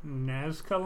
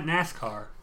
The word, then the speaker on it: Nazca: I'd get one of the Nazca lines. The things in Peru that you see from helicopters. – Okay. – I think that's how you pronounce it. – Sure. – Not NASCAR.